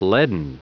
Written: leaden

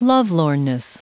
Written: lovelornness

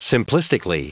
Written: simplistically

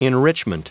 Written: enrichment

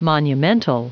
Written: monumental

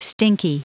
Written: stinky

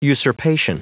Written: usurpation

